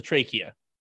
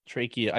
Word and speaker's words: trachea: It's rare to get a girl to like me because he has a trachea. He's had it basically his whole life. Trachea. I said that wrong. Trachea.